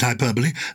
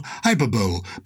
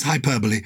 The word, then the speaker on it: hyperbole: I pronounce it hyperbole. Some people say, as in Super Bowl, hyperbole, but it is hyperbole.